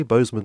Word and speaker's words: Bozeman: This is taking place, and I've said this before on the podcast, later this month, 23rd, 24th of June, in Bozeman, Montana. I hope I've pronounced that correctly, Bozeman, Montana. – Yeah,